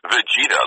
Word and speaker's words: Vegeta: You primarily know this man as Vegeta. I can't even say the name right. Vegeta? – It's actually Vegeta, like vegetable, but that's okay.